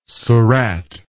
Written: surat

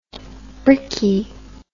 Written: brickie

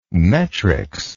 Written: metrics

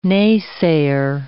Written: naysayer

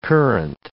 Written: currant

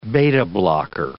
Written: beta-blocker

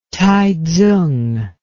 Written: Taitsung

